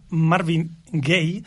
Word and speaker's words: Marvin Gaye: composada pel malaurat autor afroamericà Marvin Gaye, Uh, ho he pronunciat bé?